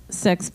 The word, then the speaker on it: CIX: It's pronounced Six, but C-I-X. – C-I-X was formed by C9 Entertainment.